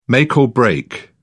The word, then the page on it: make or break: British English